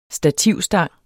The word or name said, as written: stativstang